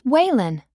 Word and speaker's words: Whalen: It's Whalen. – How do you spell that? – W-H-A-L-E-N.